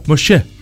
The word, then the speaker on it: Moshe: Vamos a estar hablando de Moisés, que se pronuncia Moshe.